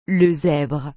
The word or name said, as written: le zèbre